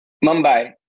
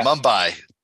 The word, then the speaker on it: Mumbai: Mumbai? How do you say it? – Mumbai. Yeah. – Mumbai.